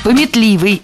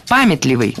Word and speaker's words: памятливый: Пометливый – это неверно, правильно, памятливый. Ударение в этом слове ставим на первый слог.